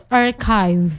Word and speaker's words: archive: Tapi aku sering banget nemuin orang tuh mispronounce cara menyebutkan arsip ya. Tulisannya kadang tuh mereka kayak nyebut archive. Sebenarnya archive ya. Jadi pronunciation-nya itu archive.